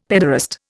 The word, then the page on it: pederast: Indian